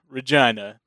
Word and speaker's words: Regina: And then there was Regina, the city that rhymes with fun.